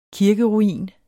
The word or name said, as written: kirkeruin